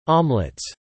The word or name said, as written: omelets